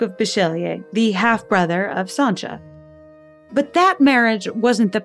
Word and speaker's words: Sancia: a girl named Sancha, although seeing her name spelled out, S-A-N-C-I-A, you might be forgiven for mispronouncing it Sansa.